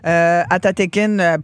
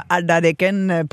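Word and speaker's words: Atateken: Vous avez entendu dans nos nouvelles la fameuse rue Amherst qui a été rebaptisée d'un nom Mohawk, Atatekin, prononciation québécoise, Atatekin, prononciation un peu Mohawk.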